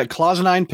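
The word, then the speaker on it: cloisonné: How to pronounce it, the Clausenine pins or whatever. – Yeah, Clausenine pin. Very good.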